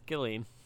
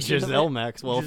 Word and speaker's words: Ghislaine: It's Ghislaine.